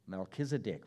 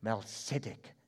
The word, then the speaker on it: Melchizedek: Melchizedek, we pronounce him in English. But in Hebrew, Melchizedek.